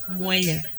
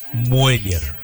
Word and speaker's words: Mueller: ¿Cómo se pronuncia realmente? – Mueller. – Mueller, yo siempre dije Mueller. – Mueller. – ¿Cómo bailaba ya? Bueno, algunos le dicen Mueller, pero lo correcto en español es Mueller, en español.